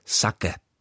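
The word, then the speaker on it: saké: Slovo sake, které označuje typicky japonské víno připravované z loupané rýže, se v češtině píše s dlouhým e na konci. Originální japonská výslovnost poslední samohlásky je ale krátká, takže by se mělo psát spíš sake. To dlouhé e na konci slova sake, které vyžaduje český pravopis, se tam dostalo asi analogicky podle slov jako kafé nebo maté.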